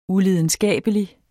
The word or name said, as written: ulidenskabelig